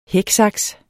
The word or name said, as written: hæksaks